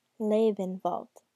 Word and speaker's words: Lebenwald: "Oh, that's pronounced Lebenwald. Go ahead and say it. German is fun."